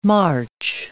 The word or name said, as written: March